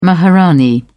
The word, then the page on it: maharani: British English